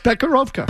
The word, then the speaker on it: Becherovka: Becherovka.